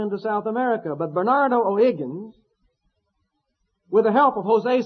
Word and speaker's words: O'Higgins: And then in 1818, a fellow by the name of Bernardo O'Higgins, as they say it there, O'Higgins. H's don't get pronounced.